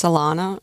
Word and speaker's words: Alana: And I apologize. I believe I was mispronouncing your name. I thought it was Alona. It's Alana? – It's been Americanized, yes. It's Alana. In Ukrainian, it sounds different, but we decided to.